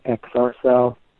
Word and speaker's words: XRCEL: And a lot of times, I just either, I've got a product that I use called XR Cell. It's spelled XRCEL.